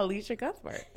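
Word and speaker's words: Elisha Cuthbert: Alicia Cuthbert? Is that her name? – Yeah, Alicia Cuthbert.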